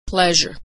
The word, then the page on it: pleasure: zh